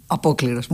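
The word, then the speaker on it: Apocliros (Απόκληρος): Vamos a escuchar entonces del compositor griego Vasilis Tsitsanis, que vivió entre los años 1915 y 1984, Apocliros. ¿Se dice así? – Apocliros, muy bien.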